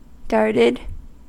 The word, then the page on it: darted: American English